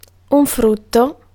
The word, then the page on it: profit: France; /pʁɔ.fi/